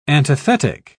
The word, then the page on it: antithetic: British English